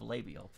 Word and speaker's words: labial: I did not say labial pits. – Okay, great. – I said l'oreal pits. They have labial pits.